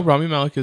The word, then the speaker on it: Rami Malek: Rami Malek. If I'm probably so butchering his name. – No, Rami Malek is right.